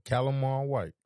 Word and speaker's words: Calimar White: Calimar White.